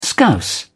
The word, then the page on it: scouse: British English